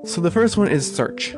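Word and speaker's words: SearX: Sirix, or Search, I think it's probably pronounced Search, is an open source search engine that searches all the other search engines the same time.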